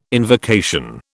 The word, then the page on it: invocation: British English